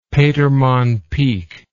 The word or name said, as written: petermann peak